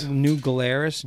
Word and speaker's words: New Glarus: New Glarus, G-L-A-R-U-S, yep.